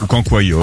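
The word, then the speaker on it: cancoillotte: Moi, je n'arrête pas de voir ça sur les réseaux sociaux, sur TikTok, sur Instagram, le cancoyote. – Oui. – Ça se prononce comme ça? – Ouais, ça se prononce comme ça. – Comme ça. – Oui, cancoyote.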